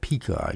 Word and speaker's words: Pekah: And Pekahiah, and then Pekah, P-E-K-A-H. Could pronounce that Pekah, I guess, but I'm pronouncing Pekah.